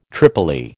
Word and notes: tripoli: American English

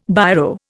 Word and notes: Biro: Indian

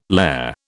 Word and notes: lehr: British English

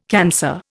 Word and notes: cancer: Indian

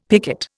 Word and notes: picot: Indian

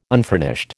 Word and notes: unfurnished: American English

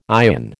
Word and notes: ion: American English

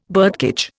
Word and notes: birdcage: Indian

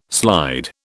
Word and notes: slide: British English

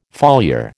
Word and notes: foliar: American English

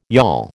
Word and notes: yawl: American English

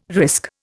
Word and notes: risk: Indian

